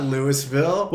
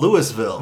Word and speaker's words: Louisville: Our friend Mark used to Louisville, not Louisville. – Well, the one up here is called Louisville. – No, it's Louisville. – And it's spelled the same way. – No, Louisville.